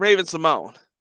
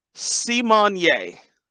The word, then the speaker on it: Simone: Her name is not Raven Simone. It's See-Mon-Yay. It's Raven See-Mon-Yay.